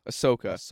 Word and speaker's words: Ahsoka: Ahsoka. – Sure. – Did I say it wrong? – Yeah, we'll take that. – Yeah, I'll take it. – Is it Ahsoka? – Ahsoka. – Ahsoka.